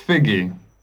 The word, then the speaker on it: Figgie: Max Figgy. – Could you spell that, please? – F-I-G-G-I-E.